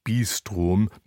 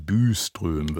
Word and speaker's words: Byström: Eine tolle Sängerin, jedenfalls eine sehr gute, ist die schwedische Sopranistin Malin Byström. Nein, Byström wird sie ausgesprochen,